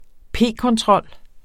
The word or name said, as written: p-kontrol